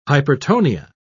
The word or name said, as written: hypertonia